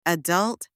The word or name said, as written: adult